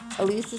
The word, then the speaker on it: Ilise: Elise is spelled I-L-I-S-E.